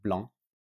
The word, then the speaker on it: blanc: And no C at the end of blanc.